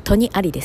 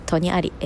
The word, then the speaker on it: 在戸: えっ、ー、と、はい、キリギリスが、あ、戸にありですね。キリギリス、戸にあり、えー、扉の戸にあると書いて、キリギリスが扉にいる、そんな簡単、あの、感じですね、を書きます。